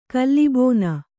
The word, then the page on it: ಕಲ್ಲಿಬೋನ: Kannada